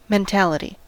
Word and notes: mentality: American English

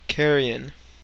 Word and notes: carrion: American English

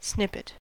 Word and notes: snippet: American English

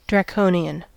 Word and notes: draconian: American English

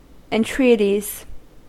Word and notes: entreaties: American English

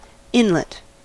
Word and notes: inlet: American English

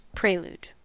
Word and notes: prelude: American English

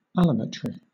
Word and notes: allometry: British English

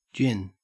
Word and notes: gin: Australian English

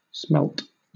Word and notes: smelt: British English

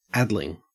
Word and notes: addling: Australian English

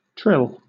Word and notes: trill: British English

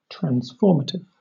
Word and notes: transformative: British English

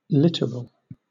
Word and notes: littoral: British English

